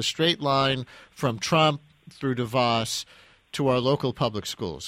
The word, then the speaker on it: DeVos: Is it DeVos, DeVos, DeVos, DeVos? – DeVos. – DeVos. Okay, so I got it right the first time.